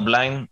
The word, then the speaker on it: Blind: Uno, eh, Blind o Blind, dependiendo de la pronunciación, el jugador de, Holanda tiene el mismo problema, tiene un marcapaso en el, corazón.